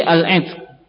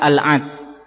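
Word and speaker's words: al-'itq (العتق): Al-itq secara bahasa adalah naam bikasril 'ain, dengan mengkasrahkan ain, jadi al-'itq bukan al-'atq. Wa sukunit ta, dan ta'nya sukun, jadi cara membacanya demikian, al-'itqu.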